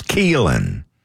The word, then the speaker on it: Keelon: It's not Keelon Russell, like it's spelled, it's Keelan. Russell.